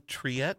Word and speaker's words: Triet: Justine Triet, am I pronouncing that right? – Yep.